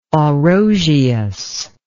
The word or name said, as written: Orosius